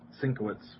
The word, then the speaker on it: Sienkiewicz: Bill Sienkiewicz, is that right? – Sienkiewicz. – Thank you.